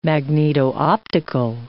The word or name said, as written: magneto-optical